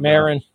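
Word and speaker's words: Maron: 2016 interview on WTF with Mark Marone, a show that was launched September of 2009. – Marin. – Thank you for that pronunciation correction.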